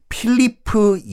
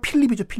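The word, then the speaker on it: Philippe: 당시 이제 프랑스 왕이 필리프 2세라는 왕이었는데 영어 발음은 필립이죠, 필립.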